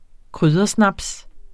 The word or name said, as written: kryddersnaps